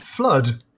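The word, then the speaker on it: FLUDD: How did you pronounce the water jetpack from Super Mario Sunshine? – I think I said fluid. – You said fluid. – It's fluid. – I've always said flood. – It's flood.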